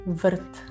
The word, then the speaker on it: врт: Сад по-сербски башта или врт. Еще раз у нас здесь р носитель а, ударения. Врт.